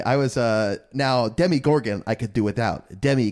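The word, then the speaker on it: Demogorgon: Yeah, so you're just like a Demigorgon. – Did I get the name right, at least? – Yeah, I think emphasis on the Demi, like Demi... Like you do, like Demi Lovato or Demi Moore.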